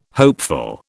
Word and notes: hopeful: British English

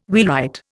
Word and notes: wheelwright: Indian

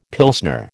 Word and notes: Pilsner: American English

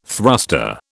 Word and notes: thruster: British English

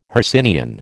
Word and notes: Hercynian: American English

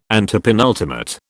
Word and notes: antepenultimate: British English

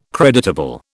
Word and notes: creditable: British English

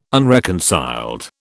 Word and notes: unreconciled: British English

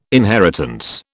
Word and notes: inheritance: British English

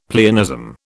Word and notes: pleonasm: British English

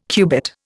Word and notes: cubit: Indian

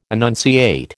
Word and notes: annunciate: American English

